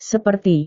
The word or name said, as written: seperti